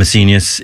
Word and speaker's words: Seignios: I'm an S-E-I-G-N-O-S. I'm a Senius. It rhymes with genius. I'm an S-E-I-G-N-I-O-S.